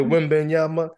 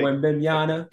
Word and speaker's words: Wembanyama: How do you say that name? – Victor Wembanyama. Yeah, that's how I've been saying it. – Say it again. Say it again. – Victor Wembanyama. Like it's like the same, like Wembanyama. – The same, like the same beat as like, that's my baby's mama. You know, that's right? My it's like mama.